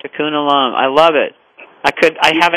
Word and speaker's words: Tikkun Olam: Tikkun Olam. – Tikkun Olam? How do you spell it? – That's right. T-I-K-K-U-N.